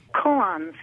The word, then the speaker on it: koan: Is it koan? – Koans, yes.